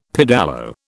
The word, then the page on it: pedalo: British English